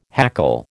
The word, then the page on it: hackle: American English